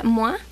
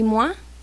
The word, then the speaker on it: Moi: Bueno, Moi, se escribe Moi, pero se pronuncia Moa, de su disco Bubu.